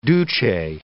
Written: duce

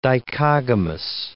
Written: dichogamous

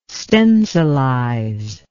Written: stencilize

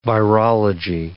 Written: virology

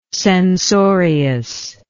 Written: censoriousness

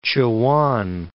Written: Chowan

